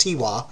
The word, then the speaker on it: Tigua: The Tiwa, that T-I-G-U-A, that's apparently pronounced Tiwa,